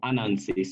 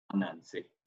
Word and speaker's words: Ananse: He's called Anansi, spelled A-N-A. – Okay. – Anansi. – Spell it one more time. You said A-N-A. – A-N-A-N-S-E. Anansi.